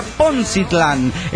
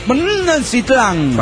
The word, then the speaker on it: Poncitlán: Bueno, se pronuncia Poncitlán, acentuando en la N. ¿Cómo se puede acentuar una N? No sé. – Acentúa la N. – Bueno, Poncitlán.